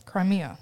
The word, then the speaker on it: Crimea: Russia had seized control of Crimea in 2014. Am I saying that right? – Crimea, yeah.